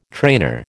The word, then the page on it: trainer: American English